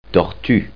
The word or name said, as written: tortue